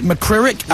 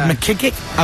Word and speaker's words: McCririck: I've heard it McCririck, yeah. I've heard McKickick, I've heard McCurick, McQuirick. – You do it right. – I think it's McCririck.